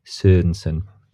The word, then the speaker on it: Sørensen: So you have to say it Sørensen.